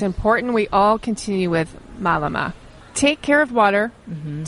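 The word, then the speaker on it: mālama: Let's talk about the Hawaiian term malama. Am I saying it correctly? Malama? – Malama.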